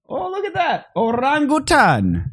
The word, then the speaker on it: orangutan: Orangutans? – It's orangutan. Orangutan. It's just not orangutan. There's no G.